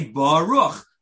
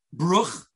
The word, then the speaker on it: Baruch: When you say Baruch, well, you should say Baruch, not Bruch or not Birich, pronounce it correctly.